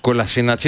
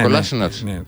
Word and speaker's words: Κολασίνατς: Κολασίνατς είναι, όχι Κολάσινατς.